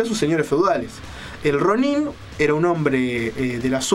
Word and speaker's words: Ronin: Ronin, o Ronin, en su pronunciación correcta, se llama algo así como el hombre ola.